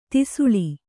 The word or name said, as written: ದಾಸಳ